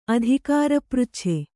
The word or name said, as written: ಅಧಿಕಾರಪೃಚ್ಛೆ